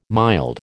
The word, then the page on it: mild: American English